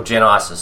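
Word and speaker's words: Genisys: Main question is, so this, the Genesis, or Genysis, sorry, I mispronounced it.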